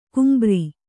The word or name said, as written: ಗಂಭೀರ